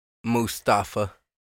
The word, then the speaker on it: Mustafa: Mustafa. – Gunther versus Mustafa Ali for the Intercontinental Championship.